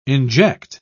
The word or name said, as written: inject